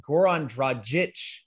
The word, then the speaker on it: Goran Dragic: Goran Dragic. That's right. I nailed the pronunciation. I looked it up on YouTube.